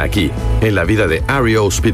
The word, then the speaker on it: REO: Al principio, sus miembros le decían Rio, pero con el fin de diferenciarse de la marca de camiones, decidieron usarlo en forma de iniciales, lo que dejó el grupo como REO Speedwagon y como lo estamos pronunciando correctamente aquí en el programa.